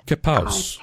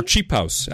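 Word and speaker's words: qipao: Kipaos. Okay. Or Chipaos, actually, because Q is often pronounced like CH.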